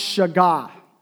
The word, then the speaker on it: shagah: The Hebrew word here for captivate is pronounced shagah.